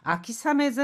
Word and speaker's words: 秋雨: 자, 여기 비 우자를요, 아메가 아니라 사메라고 읽습니다. 그래서 아키사메 전선 뭐 가을 장마 전선도 얕 と消滅したみたいですよ。드디어 소멸한 모양이에요.